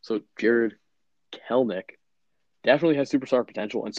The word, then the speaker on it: Kelenic: His name is spelled K-E-L-E-N-I-C. You'd think, oh, it's Kelenic. No, it's Kelnick. Yeah, sucks.